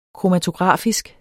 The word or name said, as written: kromatografisk